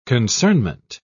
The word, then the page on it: concernment: British English